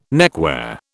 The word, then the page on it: neckwear: British English